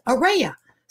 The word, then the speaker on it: Araya: It's Araya. Some people say it's like Araya Sunshine, but other people say it's pronounced like Araya Hope.